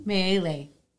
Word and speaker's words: Miele: We have Kristen Miele.